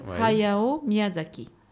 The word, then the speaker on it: Hayao Miyazaki: Ah, donc, il s'appelle Hayao Miyazaki.